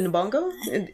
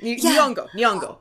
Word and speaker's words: Nyong'o: But she's played by Lupita Nyong'o. Is that how we pronounce her name? – Yes. – Nyong'o. – Yeah. Nyong'o.